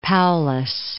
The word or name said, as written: Paulus